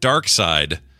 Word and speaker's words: Darkseid: Just a quick note to say I found multiple sources that confirmed Jack Kirby himself pronounced it Dark Side. He says it is a Germanic pronunciation of the villain's name.